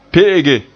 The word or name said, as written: pe'ege